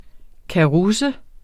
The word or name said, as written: karusse